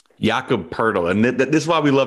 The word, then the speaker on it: Poeltl: I do like one player prop in this game is Jakob Poeltl, or Poeltl. I don't know how to say his name. – Poeltl. – Okay, there you go.